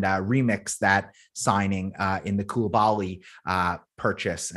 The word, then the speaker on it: Koulibaly: They've just signed Koulibaly. How do you say his name again? – Koulibaly.